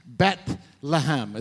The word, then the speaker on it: Bethlehem: The word Bethlehem, Bethlehem is how you say it in Hebrew, Bethlehem.